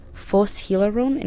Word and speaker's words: Phos Hilaron: First was Relaxation Music with Tanbor, and then after that, it's roughly translated to Joyous Music, but it's pronounced Phos Hilaron in Greek, I think.